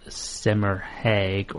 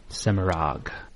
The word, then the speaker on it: Semirhage: She may be one of the most mispronounced names in Wheel of Time. It is not Semirhag or Semirhage, Semirag.